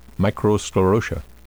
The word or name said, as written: microsclerotia